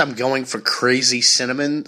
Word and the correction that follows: synonym: How do you say that word? – Cinnamon? You mean? – No. – Cinnamon buns? – No. Like SAT. Synonyms. – What? – It's the cold that's affecting me. Synonym.